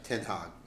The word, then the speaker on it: Ten Hag: Yeah, Ten, Ten Hag is the Dutch.